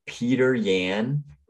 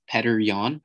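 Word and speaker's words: Petr Yan: That's not. I don't think that's how you say it. – No, it's definitely not how you say his name. But I don't know how to say it either. Petter Yan. – I think it's Petter Yan, yeah.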